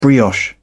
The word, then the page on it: brioche: British English